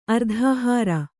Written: ಅರ್ಧಹಾರ

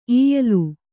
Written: ಈಯಲು

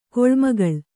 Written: ಕೊಳ್ಮಗಳ್

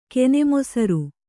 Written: ಕೆನೆಮೊಸರ್

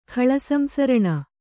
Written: ಖಳಸಂಸರಣ

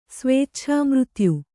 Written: ಸ್ವೇಚ್ಛಾಮೃತ್ಯು

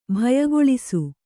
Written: ಬಾಯ್ಕೇಳಿಸು

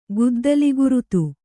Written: ಗುದ್ದಲಿಗುರುತು

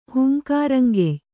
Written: ಹೂಂಕಾರಂಗೆಯ್

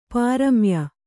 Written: ಪ್ರಮೇಯ

